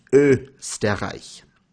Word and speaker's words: Österreich: It is pronounced Österreich.